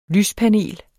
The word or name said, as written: lyspanel